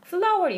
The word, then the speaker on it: flour: flour, 이거 밀가루거든요? – 네. – 네. 밀가루인데 발음이 꽃이랑 같아요.